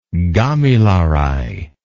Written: gamilaraay

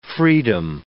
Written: freedom

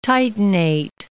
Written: titanate

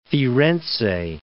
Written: Firenze

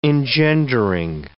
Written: engendering

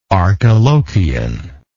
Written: Archilochian

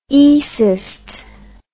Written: oecist

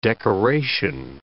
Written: decoration

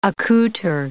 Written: accoutre

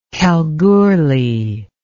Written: kalgoorlie

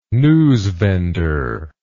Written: newsvendor

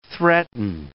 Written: threaten

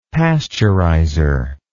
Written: pasteurizer

